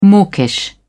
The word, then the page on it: mawkish: British English